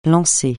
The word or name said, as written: lancer